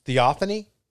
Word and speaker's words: Theophany: Theophany? – Yes.